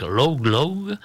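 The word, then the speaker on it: Laugh Laugh: J'espère que j'ai bien prononcé. Bah, si toutefois vous faites la recherche sur YouTube, je préfère vous donner les lettres une par une. Voilà, c'est plus simple que ma prononciation d'anglais que je ne connais pas du tout. Donc ça s'écrit L-A-U-G-H, plus loin, L-A-U-G-H. Log, log, ben voilà.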